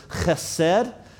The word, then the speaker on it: chesed: but it's the word chesed. All right, so you got to get a little phlegm. You got to get the back of your throat involved here, but it's chesed is how you pronounce this, but it's a word that means mercy.